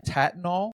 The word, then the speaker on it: Tattnall: So this is in Tattnall. T-A-T-T-N-A-L-L. Tattnall. – Georgia. – Definitely how it's pronounced.